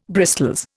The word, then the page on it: bristols: Indian